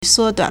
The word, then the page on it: 缩短: suōduǎn